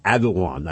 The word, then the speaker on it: Erdogan: T-A-Y-Y-I-P is his first name. E-R-D-O-G-A-N is his last name. I'm told it is actually pronounced Erdogan. I've been initially pronouncing it Erdogan, and then Erdogan. I guess it's actually Erdogan, according to people who are natives of Turkey and speak the language. So Erdogan actually is E-R-D-O-G-A-N.